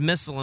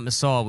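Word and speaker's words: Missal: By the way, her name is Missile and not Missile. We've been saying this wrong this entire time.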